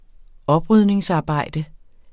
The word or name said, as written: oprydningsarbejde